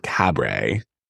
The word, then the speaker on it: Cabret: So Brian Selznick wrote, I actually think he pronounces it Cabret, The Invention of Hugo Cabret. – As in life is a? – Life is a cabaret.